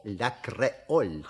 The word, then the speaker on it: Ле Креоль: Себастьян Ле Креоль... Правильно? – Не совсем. В слове «Ле Креоль» ударение должно быть на букве «О». «Ле Креоль».